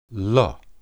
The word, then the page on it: le: Hebrew